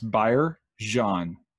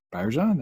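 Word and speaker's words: Byargeon: B-Y-A-R is Byer, and then the G-E-O-N is pronounced like Z-H-I-O-N, Jean. So it's Byer Jean. – Byer Jean. – Byer Jean, that's it.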